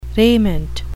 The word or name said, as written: raiment